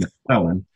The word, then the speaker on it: McClellan: The only thing I will say that my last name, which I will tell you, you're not the first. It's pronounced McClellan.